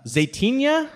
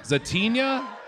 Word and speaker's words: Zaytinya: how do you say it? Zaitania? Zaytinya, Zaytinya, Jose Andres restaurant.